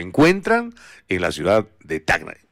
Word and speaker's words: Tacna: No estoy diciendo Tacna, no te, me dijeron, no se dice Tacna, se dice Tacna porque es con C, no es con G.